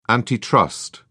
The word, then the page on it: antitrust: British English